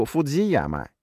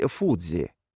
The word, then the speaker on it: Фудзи: Наше с Виктором сегодняшнее настроение было тоже прекрасным, ведь мы решили отправиться на гору Фудзияма, или, как здесь правильно говорить, Фудзи.